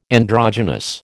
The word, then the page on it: androgynous: American English